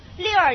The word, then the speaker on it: lure: lure 你看它发音六耳哈，六个用六个诱饵来诱骗你，是不是？